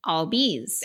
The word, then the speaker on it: Albies: One of my favorite play-by-play guys, the one who does the Nationals, Bob Carpenter, said he learned how to say this last name by talking to an Atlanta broadcaster who said, "What did you get on your report card?" – Albies. – There you got it.